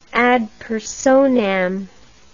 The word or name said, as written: ad personam